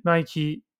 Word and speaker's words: Nike: Burberry'nin tepesinden işte Nike diye okunuyor Amerika'da. Türkiye'de Nike diye okuyoruz.